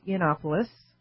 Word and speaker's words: Yiannopoulos: I believe it's pronounced Yiannopoulos.